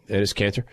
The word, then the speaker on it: Enes Kanter: Enes Kanter.